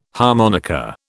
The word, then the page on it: harmonica: British English